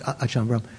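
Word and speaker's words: Ajahn Brahm: How do they say his name? Longer Achan, just Achan Brahm, or is it some other name? Achan Brahma, just Achan Brahm.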